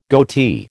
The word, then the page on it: goatee: American English